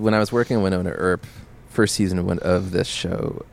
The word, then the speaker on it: Wynonna Earp: Yeah, Wynonna Earp, yes.